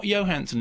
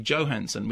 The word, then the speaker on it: Johansson: Not Johansson, incidentally, Johansson. We have to be correct about this. Scarlett Johansson. – Did she tell you that? – No, it's a known fact. That's how her name is pronounced. Everyone calls her Johansson, but it's Johansson.